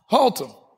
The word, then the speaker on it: Haltom: Under the tent in, how do you pronounce that town? I just figured out Watauga. Now I got this Haltom. Say it again. Haltom. That shouldn't be that difficult.